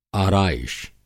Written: आराईश

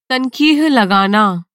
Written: तनक़ीह

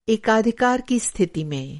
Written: एकाधिकार

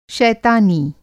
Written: शैतानी

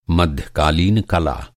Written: कला

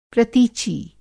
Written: प्रतीची

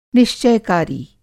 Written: निश्चय-कारी

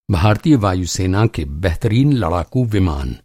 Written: लड़ाकू-विमान

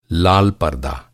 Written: परदा

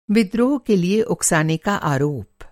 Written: उकसाना

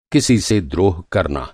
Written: द्रोह

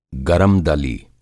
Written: गरमदली

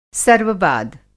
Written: सर्ववाद

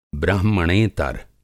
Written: ब्राह्मणेतर

